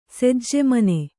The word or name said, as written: ಸಜ್ಜೆಮನೆ